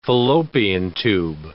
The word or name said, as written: fallopian tube